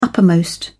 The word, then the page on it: upmost: British English